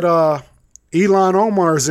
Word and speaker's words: Ilhan Omar: How did you say that? – Ilhan Omar. – Yeah. – Ilhan Omar, whatever the fuck it is. – No, it's right. You're right.